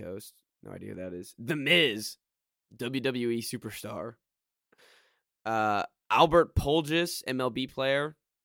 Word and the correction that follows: Pujols: Pujols? Oh, dear God. – Why? What's wrong with him? – Pujols? – Yeah. – No, your pronunciation of it. – Oh, okay. – He's like one of the best players of all time. Holy So how do you say it? – Pujols. – Pujols, all right.